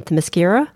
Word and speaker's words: Themyscira: Everybody else in Themyscira, is that how you pronounce it? – Yes.